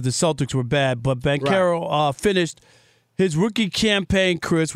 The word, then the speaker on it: Banchero: Okay, Banchero, is that it? How you say it? Banchero? – Banchero.